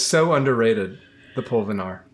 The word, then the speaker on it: pulvinar: So I always get excited when somebody brings up the pulvinar.